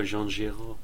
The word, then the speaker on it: Jean Giraud: Oh, Jean Giraud.